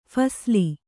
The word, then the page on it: ಫಸಲು: phasalu